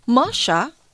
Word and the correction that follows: Mahsa: did I pronounce the name well? I think Masa.